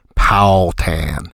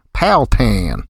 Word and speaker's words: Powhatan: The townsfolk tended to slur the three syllables into two, which I tried in my Yankified way, Powhatan. After being corrected several times, I finally threw caution to the wind and twanged up my pronunciation to sound like them, Powhatan. And that did the trick.